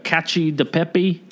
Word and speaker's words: cacio e pepe: Oh, I made the cacio e pepe. – Mm-hmm. – I know I'm mispronouncing that. I called it cacao. And Kyle corrected me. Cacio e pepe.